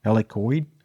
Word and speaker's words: Helicoid: I can't pronounce it because I'm American, but it's spelled H-E-L-I-C-O-I-D. Helicoid? – Helicoid, we'll go for that.